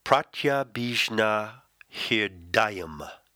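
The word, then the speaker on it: Pratyabhijñāhṛdayam: And this word is spelled as follows. Pratyabhijnahardayam, which is pronounced Pratyabhijnahirdayam.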